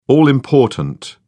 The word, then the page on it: all-important: British English